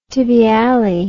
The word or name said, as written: tibiale